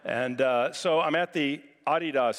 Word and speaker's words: Adidas: In London, it's pronounced Adidas. In Europe, it's pronounced Adidas. It's a German company. It's not Adidas, just preparing you. So if you ever go there, you won't sound ignorant.